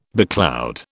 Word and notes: becloud: British English

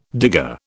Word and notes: digger: British English

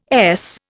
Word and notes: Es: Indian

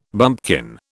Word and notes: bumpkin: British English

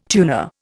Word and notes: tuner: Indian

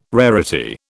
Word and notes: rarity: British English

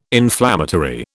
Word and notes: inflammatory: British English

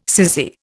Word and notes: cissy: Indian

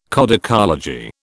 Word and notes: codicology: British English

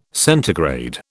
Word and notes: centigrade: British English

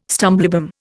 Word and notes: stumblebum: Indian

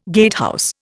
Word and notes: gatehouse: Indian

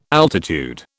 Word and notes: altitude: British English

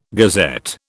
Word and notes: gazette: British English

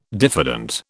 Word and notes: diffident: British English

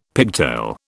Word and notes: pigtail: British English

